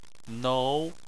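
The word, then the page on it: nou: tone 2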